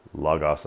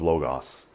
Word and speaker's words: Logos: He's from with a more in-depth discussion on the history of Logos and the Logos of history, but he pronounces it Logos because he's from Philadelphia.